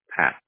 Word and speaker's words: Pat: Pat. – How do you spell it? – P-A-T. – P-A-T.